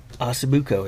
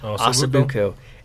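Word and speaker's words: osso buco: I've been wanting for a long time to have Osabuco. Did I say it right? – Yep. – Osabuco.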